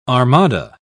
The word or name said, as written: armada